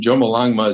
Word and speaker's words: Chomolungma: Chomolungma is actually the more correct Tibetan pronunciation for it.